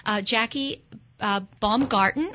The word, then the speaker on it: Jackie Baumgarten: Jackie Baumgarten.